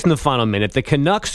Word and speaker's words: Canucks: I believe that's pronounced Canucks.